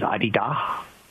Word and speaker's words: Adidas: The correct way of pronouncing it is two hard D's, Adidas, with no S.